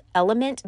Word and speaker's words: LMNT: This is by a company called Element, but it's spelled L-M-N-T.